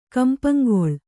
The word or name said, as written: ಕಂಪಂಗೊಳ್